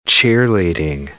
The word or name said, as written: cheerleading